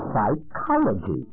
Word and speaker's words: psychology: Of course, when we bring the Greek word psyche into English, we do not pronounce that P. The P in psychology is silent like the P in swimming.